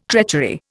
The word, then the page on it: treachery: Indian